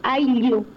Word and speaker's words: Ayllu: Así que para quienes quieran, Aiyu se escribe AYLLU, Aiyu.